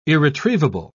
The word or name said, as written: irretrievable